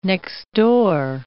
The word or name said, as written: next-door